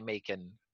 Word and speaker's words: Macon: Peter Macon or Macon? How do we say it? – Macon, I believe. I'm 99% sure you say Macon.